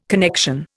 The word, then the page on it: connection: Indian